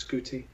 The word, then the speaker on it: Guti: It's a hard G. It's Guti. Yes.